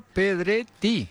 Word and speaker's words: Pedretti: Hola, Luca Pedretti. – Pedretti, exactamente. Ah, ¿lo dije bien? A ver, dígalo usted. – Luca Pedretti. – ¿Eso usa doble T? – Doble T, italiano.